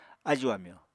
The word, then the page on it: ájù-amyò: apatani